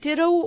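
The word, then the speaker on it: Ditteaux: Did you notice how it's spelled, too? – Yes, I love it. It's D-I-T-T-E-A-U-X. – Right. – With a French twist, of course, you being in Louisiana.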